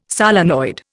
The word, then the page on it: solenoid: Indian